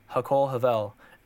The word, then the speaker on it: Hakol Havel: It's called uh, Hakol Havel, and that's spelled H A K O L, uh, H A V O L, Hakol Havel, and this is uh, Hebrew for um, everything is vapor.